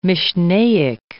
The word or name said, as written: Mishnaic